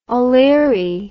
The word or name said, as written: oleary